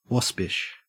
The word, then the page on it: waspish: Australian English